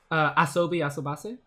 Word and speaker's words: Asobi Asobase: Like, it doesn't, like, go the far, like, as far as, like, I don't know, Usabi, how do you pronounce it again? I haven't, that really good show, Usabi Asobase, was that it? – Asobi Asobase? – Yeah, that's it.